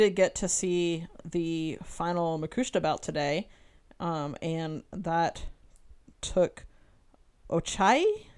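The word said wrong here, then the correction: Ochiai: Am I pronouncing that Ochi-ai. – Right? Ochi-ai. Sorry. Yeah, that's right.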